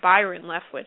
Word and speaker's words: Byron Leftwich: So we're going to get the Brian Leftwich experience again, Byron Leftwich.